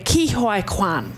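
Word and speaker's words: Ke Huy Quan: It's Ki-Hoi Kwan.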